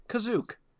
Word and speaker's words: Kashyyyk: It is Life Day, a holiday that is celebrated on Chewbacca's home planet of Kashyyyk, or as they pronounce it in this film, Kazook.